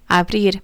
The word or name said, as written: abrir